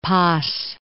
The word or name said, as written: Paz